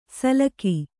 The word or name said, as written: ಸಲ್ಲಕಿ